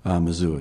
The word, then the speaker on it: Mazur: Now, the next morning, you were found by Dan, is his name pronounced Mazur, Mazur? – Mazur, Dan Mazur, yeah.